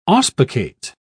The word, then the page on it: auspicate: British English